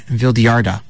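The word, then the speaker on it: Vildhjarta: All right, this is Dagger by Vildjarda. – Yeah, see, I pronounced it right. – That is how you pronounce it, because they're Swedish.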